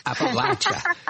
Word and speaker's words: Appalachia: Appalachia.